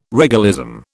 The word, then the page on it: regalism: British English